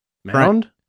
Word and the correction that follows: Mond: Oh, Mond. – Kellen Mond. I mispronounced that.